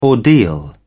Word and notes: ordeal: British English

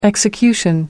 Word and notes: execution: American English

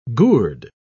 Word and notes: gourde: British English

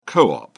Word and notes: co-op: American English

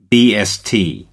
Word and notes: bst: American English

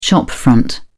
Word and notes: shopfront: British English

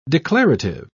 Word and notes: declarative: British English

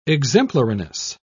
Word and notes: exemplariness: British English

